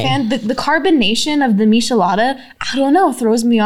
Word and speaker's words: Michelada: Michelada.